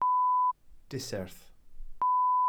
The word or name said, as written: diserth